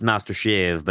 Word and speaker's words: Master Chef: It's a let, let live live Therian name, and uh, it's pronounced Master Shave, but that's difficult.